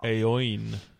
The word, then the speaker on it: Eoin: All right, so the next one is from Owen Donnelly, whose name is spelled E-O-I-N, but he very conveniently told me how to pronounce it at the end of the letter.